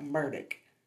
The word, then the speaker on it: Murdaugh: Now, if you are from the Low Country, you pronounce Murdaugh as it is spelled, okay? Because it is literally M U R D A U G H. Yeah, Murdaugh. But if you're from the Low Country, you would pronounce that Murdoch. I don't understand that. I will not be pronouncing that Murdoch. I will be pronouncing it Murdahl.